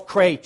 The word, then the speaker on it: Krahe: He is the Charles Krahe, am I saying it right, Dr. Tipton? Okay, all right. Uh, Charles Krahe Chair of Biblical and Systematic Theology at Westminster Theological Seminary in Philadelphia, Pennsylvania.